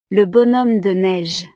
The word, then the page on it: le bonhomme de neige: frances